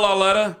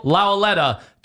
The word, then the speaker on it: Lauletta: And I think Kyle Lauletta. – Kyle Lauletta.